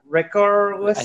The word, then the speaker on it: Richarlison: Richarlison. – I think it's like I think Richarlison? it's like Richard listen, Richard Yeah.